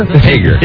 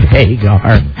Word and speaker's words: Hager: Is it Hager? – Hager. – You said Hagar. – Hagar. – Hey. Like Sammy Hagar. – Like Hagar the horrible.